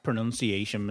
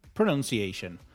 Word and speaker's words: pronunciation: Pronunciation, not pronunciation. Somebody once told me on social media that I actually said pronunciation. I've never said pronunciation in my life. So whoever you are, I love you, but you're wrong.